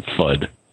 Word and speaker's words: FUD: I pronounce that FUD.